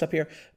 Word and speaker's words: tapir: It's the same as the tapir at the beginning. – Yeah. – Or it's pronounced tapir.